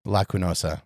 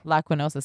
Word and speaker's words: Lacunosa: Anyway, it's a Lucosa? Lucosa? – Lacunosa. – Lacunosa. See, I can't pronounce nothing.